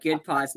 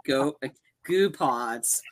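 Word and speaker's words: Goodpods: Good pods, not goo pods.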